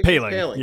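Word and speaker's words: Poehling: Did we decide on that pronunciation? – Paling. Yeah, Paling.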